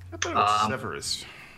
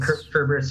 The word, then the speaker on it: Cerberus: Severus. – No, Cerberus is the proper. That I pronounce it Severus as well, but the proper pronouncing is Cerberus.